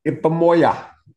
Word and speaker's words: Ipomoea: Ipomoea.